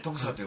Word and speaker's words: 徳佐: あ、あれでね、徳佐って読むんですね。 – そういう徳佐って読むらしいです。